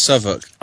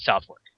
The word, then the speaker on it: Southwark: It's pronounced Suffolk. Even I don't know why. – Well, guess what? I'm in America, and we call it South Fork.